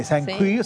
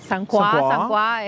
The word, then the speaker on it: Saint Croix: Está Saint Queer, sí. – Saint Croix es como se pronuncia.